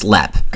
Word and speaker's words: Leap: Now, Lepp is spelled L-E-A-P, so we would say leap, but it's pronounced Lepp.